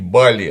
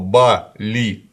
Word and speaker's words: Бали: Как мне объяснили индонезийцы, что никакое оно не Бали, оно Бали, там два ударения. И не Бали и не Балено, Бали. Да. Э, оба слога ударные, поэтому не выделывайтесь.